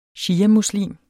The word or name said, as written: shiamuslim